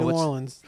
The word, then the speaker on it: New Orleans: No, New Orleans.